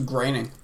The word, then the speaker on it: Groening: I thought it was graining. – Graining. Oh, that makes more sense.